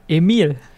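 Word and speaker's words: Emile: Ist allerdings dann irgendwann nach äh, Amerika gegangen und hat sich da Emil genannt, also französisch ausgesprochen.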